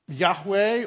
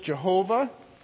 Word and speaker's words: Yahweh: L-O-R-D, all caps. And that is shorthand to represent the divine name of God. The divine name of God that is pronounced Yahweh or Old English Jehovah.